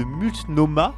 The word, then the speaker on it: Multnomah: Le comté de Multonome. De de Multnomah, pas évident à prononcer, le comté de Multnomah dans l'Oregon réclame 51 milliards de dollars aux compagnies pétrolières jugées responsables d'un épisode climatique meurtrier en 2021.